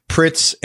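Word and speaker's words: Prutz: It's spelt C-I-R-K-U-S, Circus in Sweden, Pritz, and it's P-R-U-T-Z. But if you just look up C-I-R-K-U-S, I'm pretty sure you'll get Circus Pritz coming up.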